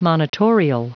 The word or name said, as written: monitorial